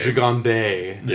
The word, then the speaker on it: Gigandet: Gigandet. I think you know how to pronounce his last name. – Gigandet.